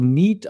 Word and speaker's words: NEAT: Und da gibt es natürlich wieder einen Fachbegriff dafür, das wird also NEAT ausgesprochen, also Non-Exercise Activity Thermogenesis.